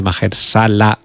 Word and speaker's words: Mahershala: acompañada de Mahershala, sí, lo he dicho bien, Mahershala Ali,